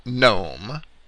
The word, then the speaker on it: gnome: And from there, in certain European languages, the G was eventually dropped from the pronunciation. And there are clear examples of that in the English words gnome, G-N-O-M-E, and gnu, which is G-N-U, referring to the animal.